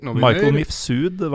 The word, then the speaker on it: Michael Mifsud: Michael Mifsud var det en taxisjåfør som sa at ja. det uttales.